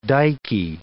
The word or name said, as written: dykey